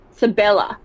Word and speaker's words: Sibella: Sibella. That makes sense because it's literally C and Bella.